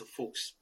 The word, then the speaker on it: Fuchs: We say the word fox with an accent. It's a Fuchs, okay?